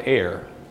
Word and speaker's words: err: Jesus answered and said unto them, now ye do, now I want to, this word E-R-R, the correct pronunciation in England is err. You do err. I have always called it air. And I think either one is correct, but err is what they do.